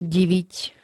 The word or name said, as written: diviť